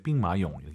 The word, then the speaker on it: 兵马俑: 병마용, 병마용 하지 마시고 이제 兵马俑 예쁘게 발음하시기 바랍니다.